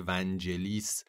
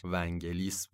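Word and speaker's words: Vangelis: ونگلیس یا اونطور که سالها به غلط مصطلح تو ایران جا افتاده ونجلیس، حتی من یادمه که زیرنویسای تلویزیون خودمون هم همواره ونجلیس میزد که بعد سالها بعد ما فهمیدیم که بابا اصلا تلفظ اصلیش ونگلیس بوده.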